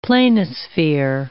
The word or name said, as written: planisphere